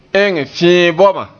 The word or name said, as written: ma